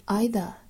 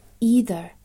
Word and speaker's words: either: 第二句，but 若读 but 就不需要 but，后面 either 这个词啊也是有两个发音，英式呢是 either，美式 either。